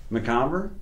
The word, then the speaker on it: Mcomber: But Ian Mcomber, right? Is that how you say your name? – Mcomber? – Yeah.